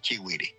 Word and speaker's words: Chigüire: cambiado y ha variado en alguna medida la pronunciación del nombre, pero el nombre correcto es Chigüire.